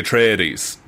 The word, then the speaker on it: Atreides: It's a, like, Atreides. Is that the pronunciation? – The Atreides.